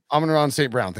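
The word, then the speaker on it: Amon-Ra St. Brown: Credit to the Amron. I can't ever say his name right. – Amon Ross St. Brown.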